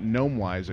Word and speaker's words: GnomeWise: GnomeWise account, you can come on in. – The G is silent.